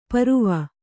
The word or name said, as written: ಪರ್ವು